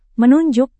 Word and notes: menunjuk: Indonesian

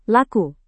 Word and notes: laku: indonesian

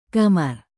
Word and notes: kamar: Indonesian

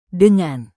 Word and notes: dengan: indonesian

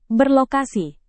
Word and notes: berlokasi: Indonesian

